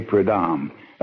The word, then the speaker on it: Prudhomme: Well, I say Prudhomme. A Frenchman really explodes it. He says, Prudhomme, or something like that.